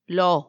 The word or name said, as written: lo